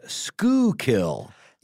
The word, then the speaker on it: Schuylkill: I always heard it as Schuylkill. – Yeah. – And, drop the L.